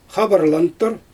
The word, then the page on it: xabarlantur: Xyzyl